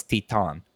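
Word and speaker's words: Titane: Titan, but it's a French movie, so it's Teton. – Teton. – It looks like Titan.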